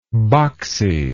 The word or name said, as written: boxiness